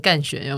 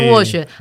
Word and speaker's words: 斡旋: 有有些人会不小心念成干旋，有没有？哎，但他其实念斡旋，还好啊，反正就是词嘛，大家常常念错啊。